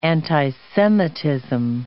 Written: anti-Semitism

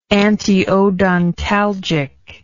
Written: antiodontalgic

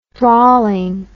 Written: drawling

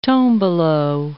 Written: tombolo

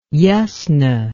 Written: yasna

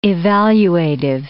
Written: evaluative

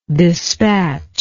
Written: despatcher

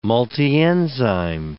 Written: multienzyme

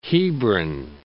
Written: Hebron

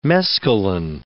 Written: mescaline

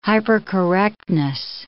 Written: hypercorrectness

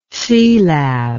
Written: Sealab

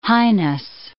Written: highness

